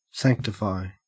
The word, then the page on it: sanctify: Australian English